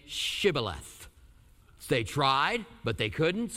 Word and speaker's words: Shibboleth: And they would say to him, then say Shibboleth. And they would say, Sibboleth, for he could not pronounce it right.